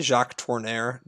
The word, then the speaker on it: Jacques Tourneur: It is Out of the Past, which some people have described as like the ultimate film noir, directed by Jacques Tourneur. How do you pronounce that? Tourneur? – Jacques Tourneur.